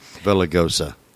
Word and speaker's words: Villaraigosa: she broke the silence that Mayor Antonio Villaraigosa, I can't pronounce his name. – Villaraigosa.